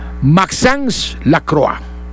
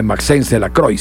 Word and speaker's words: Maxence Lacroix: Maxence Lacroix. Nada de Maxence Lacroix, sino Maxence Lacroix.